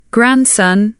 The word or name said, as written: grandson